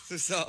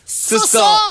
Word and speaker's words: suso: Suso. – Can we please pronounce it correctly? Kumakain ng... Suso.